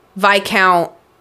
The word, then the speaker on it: Viscount: And it goes, I think it goes Duke, Viscount, Baron, something else.